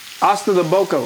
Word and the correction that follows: Ossobuco: How you say it again? – Ossobuco. – Ossobuco.